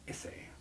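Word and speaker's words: Issey: Izzy Miyake. A scent. – Is that how you pronounce it? Izzy? – A scent. Yeah, no, that's Izzy Miyake. – Issei. Issei.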